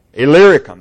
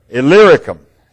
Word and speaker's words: Illyricum: Illyricum, that's exactly right. I even went online and asked, how do you pronounce that word? And I had that down. Say it one more time. Illyricum.